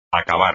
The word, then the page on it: acabar: espagnol